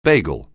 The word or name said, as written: bagel